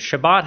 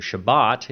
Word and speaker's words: Shabbat: but Shabbat has more of a hard B sound in the center, so that's how we're going to try and delineate the two. So Shabbat is the noun form.